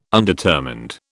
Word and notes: undetermined: British English

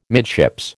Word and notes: midships: American English

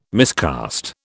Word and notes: miscast: British English

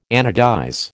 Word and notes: anodize: American English

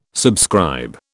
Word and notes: subscribe: British English

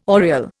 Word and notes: oriel: Indian

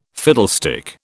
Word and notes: fiddlestick: British English

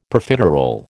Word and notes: Profiterole: American English

